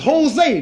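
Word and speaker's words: José: I said, man, I said, in California, all the names that start with a J, you pronounce them like they starting with an H. So this is Jose, not Joe.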